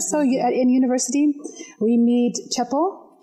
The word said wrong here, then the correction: Chepto: Chepto. – You think all the Kenyans are like, Chepto, get that T. We pronounce every consonant in this language. Yeah, I just dropped the T. Okay. So Chepto.